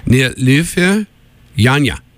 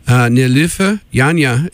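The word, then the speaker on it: Nilüfer Yanya: Latest material from, I'm going to try to pronounce her name here, is Nilufia Yanya, here on Freeform Radio, Tufts University 91.5 WMFO. You're tuned in to music you might otherwise miss. Nilufia Yanya, it's spelled N-I-L-U with an umlaut over it, F-E-R, and Yanya, Y-A-N-Y-A.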